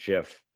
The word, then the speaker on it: GIF: It no. is actually JIF when you're talking about the picture, uh, like the uh, format. It's pronounced JIF.